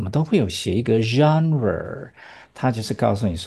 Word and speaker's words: genre: 然后英文把这个变成 r 来念，然后 g 呢也不是念 g，而是念 r，导致这个字的发音就是怪怪的。对，啊 e 呢，e 居然是念 e 的音哦，有没有觉得很怪？的确，e 字尾是不是大部分都不发音？啊，这个有发音，而且居然是念 e，所以才念成 genre。